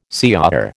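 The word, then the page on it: señor: American English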